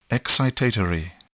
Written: excitatory

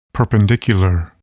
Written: perpendicular

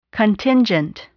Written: contingent

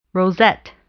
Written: rosette

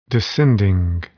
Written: descending

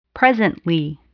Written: presently

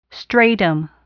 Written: stratum

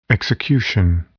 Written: execution

